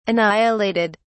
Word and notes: annihilated: British English

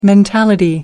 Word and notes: mentality: American English

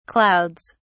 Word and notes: clouds: British English

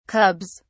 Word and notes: cubs: British English

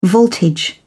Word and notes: voltage: British English

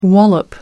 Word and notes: wallop: American English

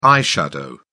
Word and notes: eye shadow: British English

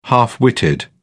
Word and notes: half-witted: British English

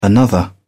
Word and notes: another: British English

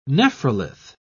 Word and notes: nephrolith: British English

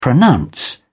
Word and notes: pronounce: British English

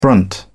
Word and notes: brunt: British English

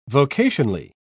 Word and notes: vocationally: British English

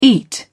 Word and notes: eat: British English

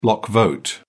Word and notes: block vote: British English

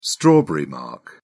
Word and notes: strawberry mark: British English

